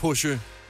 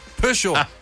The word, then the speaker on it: Peugeot: Så nu kunne jeg også høre i går, at jeg udtalte Peugeot? – Ja! – Forkert. – Nej, Peugeot. Nu gjorde du det rigtigt. – Peugeot. – Ja. – Peugeot. Pøsjo.